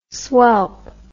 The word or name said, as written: swelp